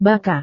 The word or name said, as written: baca